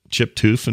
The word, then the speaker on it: Toof: He says, I knew of a family in the town I went to college in by the last name Toof, pronounced Toof, T-O-O-F, although pronounced spelled T-O-U-F.